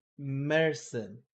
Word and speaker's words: Märzen: So Huss Brewing Oktoberfest is inspired by the traditional Bavarian Märzen. Märzen. That's how it's pronounced.